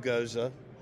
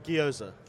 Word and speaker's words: gyoza: Wagyu goza. Did I say that right? – Gyoza.